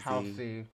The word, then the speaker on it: Halsey: Um, so is her name pronounced Halsey? Halsey. – Halsey. – Halsey, okay, Halsey.